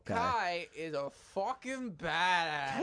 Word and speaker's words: Kia: Yeah, Kia, or known as Kai. – Okay, yeah. – The reason why I say Kia first is because it's spelled K-I-A, mm-hmm. but they're known as Kai.